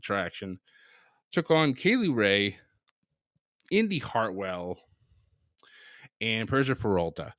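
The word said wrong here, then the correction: Pirotta: Or Parada, I'm sorry. I keep saying her name wrong. It's Parada.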